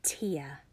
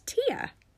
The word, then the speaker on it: Tia: Tia. That is how you pronounce my name. It's Tia. It isn't short for anything, just the middle name that my mama picked.